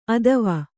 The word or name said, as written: ಅತ್ವ